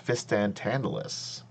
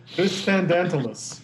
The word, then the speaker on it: Fistandantilus: Fistandantilus. Fistan Tantalus. – and Tantalus. There's there a D in there. – Anyway, you don't know what this anyway. – The, the D is silent.